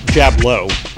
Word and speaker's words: Jablow: Jablow. Oh, like dirty fighting, like you Jablow.